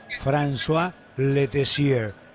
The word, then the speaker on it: François Letessier: François Letessier. ¿Le gusta cómo le pronunció el francés? – Muy bien, ¿sí?